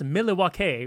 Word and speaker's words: Milwaukee: It's pronounced Milwaukee, which is Algonquin for the good land.